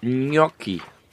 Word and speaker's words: Gnocchi: Du hast es auch gut ausgesprochen. Gnocchi.